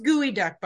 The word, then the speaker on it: geoduck: Okay, so anybody, it's pronounced gooey duck, by the way.